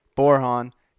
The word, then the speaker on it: Borjon: That's Joe, J-O-E, Borhan, B-O-R-J-O-N, yes, the J is silent, realtor.